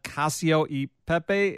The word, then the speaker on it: cacio e pepe: I think this is a kale salad, cacio e pepe inspired.